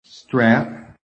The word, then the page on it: STRAP: American English